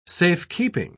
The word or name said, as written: safekeeping